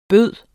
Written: byde

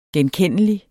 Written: genkendelig

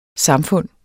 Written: samfund

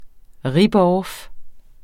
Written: ripoff

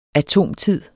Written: atomtid

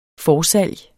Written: forsalg

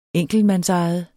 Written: enkeltmand

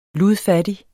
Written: ludfattig

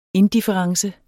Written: indifference

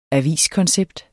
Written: aviskoncept

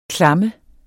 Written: klamme

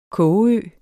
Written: kogeø

